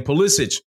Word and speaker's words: Pulisic: He showed told the Italian reporters how to pronounce his name, said he was a little closer to Croatia, so it shouldn't be hard to pronounce his name. Pulisic is his name.